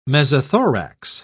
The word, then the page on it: mesothorax: British English